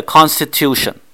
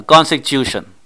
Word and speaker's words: constitution: 正確嘅發音係 constitution，但係我哋廣東人有口音發音，可以講啊 constitution。